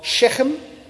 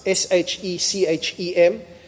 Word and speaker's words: Shechem: They move about 40 or 50 kilometers north from uh, Ai Bethel into the region of Shechem, which is really difficult to pronounce S H E C H E M. Uh, which is, uh, uh you know it's Shechem because we're told that they're going to Mount Ebal and Mount Gerizim.